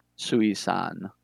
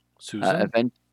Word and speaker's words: Sui-san: Sui-san. – Susan? – I suppose you could pronounce that as Susan, but it's spelled S-U-I hyphen S-A-N. – Okay. Shushan.